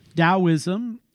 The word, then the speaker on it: Taoism: Taoism, pronounced with the D, spelled with a T. I hope I said that earlier correctly. Taoism.